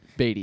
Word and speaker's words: Batty: And then Tyler Batty? – Beatty, yep. – Beatty? – Mm-hmm. – It's spelled Batty.